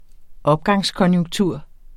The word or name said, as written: opgangskonjunktur